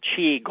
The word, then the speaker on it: qigong: Now, you really are the international expert in qigong, Gong. And for those of you listening, that's spelled Q I G O N G.